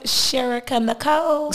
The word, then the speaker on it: Sherika Nichole: Sherika Nicole.